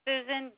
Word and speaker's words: Susan: Susan Geary, G-E-A-R-Y. – Is the first name spelled S-U-S-A-N? – Yes.